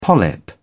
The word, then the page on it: polyp: British English